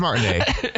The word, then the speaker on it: Martinet: Charles Martinet, who is the canonical voice. – Or is it Martinet? – Voice, it's Martinet. That's how he pronounces it.